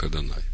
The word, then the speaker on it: Адонай: А там, где Господь, там стоит слово непроизносимое, мы его произносим как Адонай.